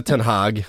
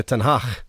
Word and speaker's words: Ten Hag: Han heter ju inte Ten Hag, han heter Ten Hag.